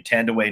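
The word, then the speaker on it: Thandiwe: And then finally, you have the best friend, who is now, and I love that this pronunciation is happening, Tandaway Newton. Who used to be Tandy Newton.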